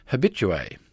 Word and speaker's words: habitué: One of the oddest anomalies of this process is habitué, which is an unassimilated French word, but which is generally spoken with a sounded H.